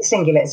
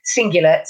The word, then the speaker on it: cingulate: So it's called the anterior cingulate. So it's spelled A-N-T-E-R-I-O-R, anterior, cingulate, C-I-N-G-U-L-A-T-E.